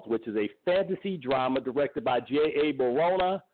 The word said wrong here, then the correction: Bayona: Excuse me, I keep mispronouncing his name. J.A. Bayona, written by Patrick Ness and based on his novel.